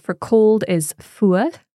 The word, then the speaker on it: fuar: So the word fuad is spelt F-U-A-R. – Yeah. – The R at the end can be pronounced differently depending on which part of the Gaelic speaking world you're in. – Okay. – I would say fuad, which, so I'm forming that R quite far forward in my mouth.